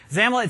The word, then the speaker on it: XAML: Now, XAML, X-A-M-L, we pronounce it XAML.